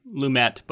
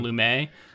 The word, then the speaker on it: Lumet: I think we both were mispronouncing Lumet before and calling him Lumet.